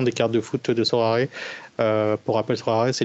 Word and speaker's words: Sorare: Et euh, là, il fera un partenariat avec euh, le Sorare, Sorare, apparemment ça se prononce.